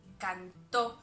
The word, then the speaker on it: cantó: Como podéis notar, en este tiempo verbal tenemos algunas cosas particulares, por ejemplo, el acento en la primera persona, canté, y en la tercera persona, cantó. Ese acento es muy importante, porque si yo no le pongo el acento a cantó, se convierte en canto.